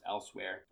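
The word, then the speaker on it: Elsweyr: It has a new expansion coming out called Moons of I think it's just Elsewhere. I know it's not spelled like Elsewhere, but I think that's how it's pronounced, Elsewhere.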